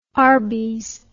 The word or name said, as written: arbys